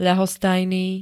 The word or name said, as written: ľahostajný